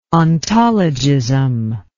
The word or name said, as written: ontologism